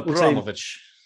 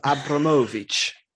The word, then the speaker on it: Abramovich: No, so there's a guy on the Abramovich. That's not how you say it. It's like you sort of roll it like it's a James Blunt song. It's Abramovich is how you say it.